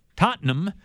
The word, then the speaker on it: Tottenham: They'll be at Hotspur Stadium, Tottenham.